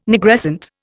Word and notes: Nigrescent: Indian

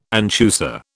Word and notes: anchusa: British English

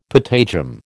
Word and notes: patagium: American English

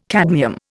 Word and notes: cadmium: Indian

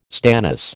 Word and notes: stannous: American English

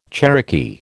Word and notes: Cherokee: American English

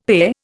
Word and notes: PE: Indian

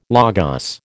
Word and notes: Logos: American English

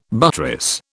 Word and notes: buttress: British English

